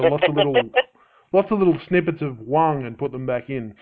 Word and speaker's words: Hwang: Oh, have I been saying it wrong? – That's Huang. Okay. No. Yeah, well, a little bit. Everybody says it differently. It's Alex T. Huang. – Huang. – H-W-A-N-G. – Okay, yes. So the H is silent.